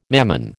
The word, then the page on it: Mammon: American English